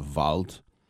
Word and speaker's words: Wald: Wald, or W-A-L-D, Wald. Wald means forest. But a W in German is pronounced like a V, and if the D comes at the end of a word, it's pronounced like a T.